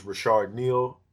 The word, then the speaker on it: Reshard Neal: So y'all know my name is Rashard Neal, R-E-S-H-A-R-D. N-E-A-L.